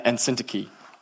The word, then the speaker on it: Syntyche: Not a big deal, but like, you're not pronouncing them quite right. And I was like, great, an hour of my life just gone, never to be recovered. So that's the best I can do based on what I studied and what Jeremy told me is Euodia and Syntyche.